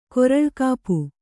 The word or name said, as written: ಕೊರಳ್ಕಾಪು